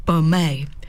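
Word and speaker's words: Baumet: The City of Perth Brass Band presents Virtuosic Brass, a dazzling afternoon concert featuring international euphonium star Bastien Beaumont. Oh, actually, it's Beaumet is how it should be pronounced, and talented musicians from within the band.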